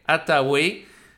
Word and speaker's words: Hathaway: je vais essayer de le prononcer comme il faut en anglais, Hathaway,